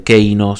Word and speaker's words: Kano: No voy a hablar de Sub-Zero, sino de Sub-Zero, y no voy a hablar de Keino, sino de Kano entre tantos otros.